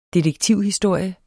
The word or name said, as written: detektivhistorie